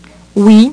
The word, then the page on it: oui: French